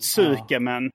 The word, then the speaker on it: tsukemen: För ett skämen, de här dipping ramen, så var det tsukemen. – Ja. – Tsukemen, men det sa att uttalat skämen. Då är det också så här, men U är för det mesta stumt.